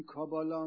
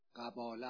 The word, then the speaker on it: قباله: این کابالا هم تلفظ فرنگیشه، اصلش قباله است، قباله، قباله، همه قباله خودمون، قباله دیگه، خانمون قباله دارن که قباله، بهش میگفتن قباله. قباله تلفظ شده به کباله و کابالا.